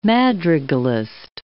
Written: madrigalist

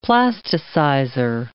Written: plasticizer

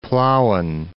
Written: Plauen

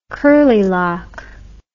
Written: curlylock

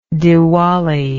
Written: diwali

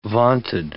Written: vaunted

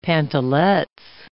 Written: pantalets

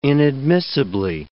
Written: inadmissibly

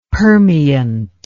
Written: permeant

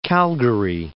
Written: Calgary

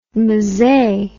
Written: mzee

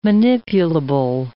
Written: manipulable

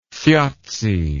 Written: thjazi

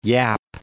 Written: Yap

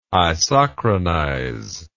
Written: isochronize

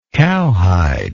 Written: cowhide

